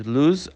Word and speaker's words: loose: Gente, olha, repara na pronúncia dessa palavra. A gente fala como se ela tivesse dois S's. Loose, loose. É diferente da palavra anterior, que é o verbo perder, que a gente pronuncia lose, ok?